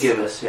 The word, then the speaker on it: gibbous: Gibbous? – Gibbous, yeah.